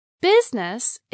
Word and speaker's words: business: Business is pronounced with two syllables, business, instead of three.